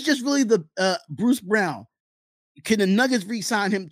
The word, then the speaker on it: Braun: He says his last name is pronounced as Brown, but it's okay.